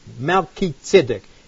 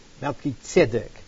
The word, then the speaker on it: Melchizedek: Now, the way I pronounce that comes from the Hebrew word, Melchizedek. Melech means king. Tzedek means righteousness. Melchizedek, the king of righteousness, which was also the king of Salem, peace.